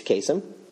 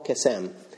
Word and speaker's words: Kasem: Now, if I'm pronouncing this right, he was born Kamal Kasem, and he changed his name at some point to Casey Kasem. He kind of put the emphasis in a different place.